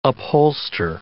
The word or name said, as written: upholster